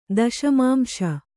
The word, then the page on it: ದಶಮಾಂಶ: daśamāmśa